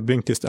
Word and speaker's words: Blinkist: Eh, blinkist är en tysk tjänst ursprungligen som, eh, som sammanfattar skönlitteratur. Så det borde uttalas Blinkist!